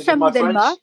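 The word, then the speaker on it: Chaban-Delmas: But also on Saturday in the lesser tournament, if you like, the Challenge Cup, Bordeaux Begler, they face Edinburgh in the Stade Chaban Delmas. Is that how it's pronounced? – Yes, Chaban Delmas.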